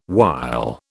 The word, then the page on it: wile: British English